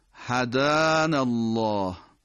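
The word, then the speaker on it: هدانا الله: یکی عبارت بعد از هدان الله، هدان الله، این کلمه هدا هست، هدان الله، جور دیگه‌ای تلفظش غلط دیگه عادتاً چون حرکت کشیده آ داره.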